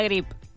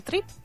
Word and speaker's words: Grip: Get a grip. – Sí. – ¿A grip? ¿A trip? – Grip. – Ah, lo dije bien. Ah, perfecto. – Lo dijiste bien.